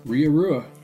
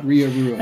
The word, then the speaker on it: Ria Rua: Is it Ria Rua? – Ria Rua. – Is that how it's pronounced? – Yep. Rua. Yep, Ria Rua.